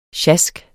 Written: sjask

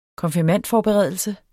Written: konfirmandforberedelse

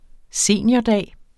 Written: seniordag

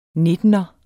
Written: nittener

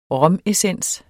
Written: romessens

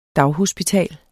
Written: daghospital

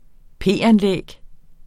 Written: p-anlæg

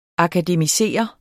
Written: akademisere

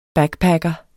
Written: backpacker

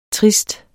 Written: trist

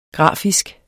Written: grafisk

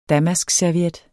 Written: damaskserviet

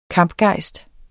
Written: kampgejst

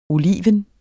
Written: oliven

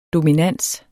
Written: dominans

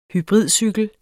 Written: hybridcykel

